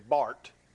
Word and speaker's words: Barth: And it's spelled Barth, but if you ever see it, if you want to impress somebody, pronounce it the way it should be, Barth.